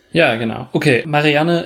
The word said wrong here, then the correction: Marianne: ist, oder Marianne, besser gesagt, ich habe das sehr deutsch ausgesprochen gerade, ist Malerin und sie bekommt den Auftrag, Eloise zu malen.